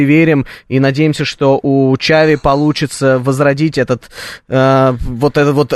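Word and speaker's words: Xavi: Хави Эрнандес, он же Чави Эрнандес, на самом деле. Правильное произношение такое, поэтому не бейте меня, когда я буду иногда называть его Чави.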